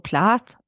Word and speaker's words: Plath: Wir hatten das doch schon mal, Sylvia Plath, wo wir auch dachten, ja, das spricht sich ja so Plath. Ne, wegen dem TH aus, aber man sagt ja auch, da hast du ja auch darauf hingewiesen, dass es Sylvia Plath heißt, das war mir ja damals auch so nicht bewusst.